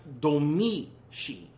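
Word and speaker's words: Domee Shi: Her name is pronounced Domeshi.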